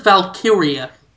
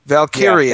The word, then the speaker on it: Valkyria: Is it pronounced Valkyria Chronicles? It's something I only read and don't ever say out loud. – I've always said Valkyria. – Valkyria, yeah, okay. That sounds more correct.